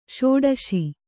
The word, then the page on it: ಷೋಡಶ: ṣōḍaśa